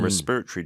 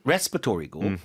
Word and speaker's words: respiratory: And respiratory distress is real discomfort breathing. – 그렇죠. 미국 발음은 respiratory고 영어 발음은 respiratory. – 그렇죠. respiratory. 아예 다른 단어처럼 들립니다.